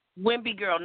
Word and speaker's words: Wimby: Wimby girl, no L, no L.